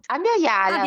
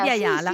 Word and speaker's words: Abya Yala: Francesca Gargallo, che nel suo Femminismo desde Abya Yala del 2012, Abya Yala, lo pronuncio bene, forse no? – Abya Yala, Abya Yala. Sì, sì, sì, è un termine fondamentale.